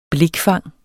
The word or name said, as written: blikfang